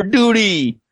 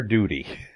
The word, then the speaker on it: duty: "You gotta do your duty." – Well, we do love our duty. All I can do about that is try to pronounce that T as hard as I can. My duty.